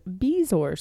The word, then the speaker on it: bezoar: How's that spelled? – B-E-Z-O-A-R-S. – That's how that's pronounced? Bezoars? – Mm-hmm. Bezoar. – I thought it was bezoar.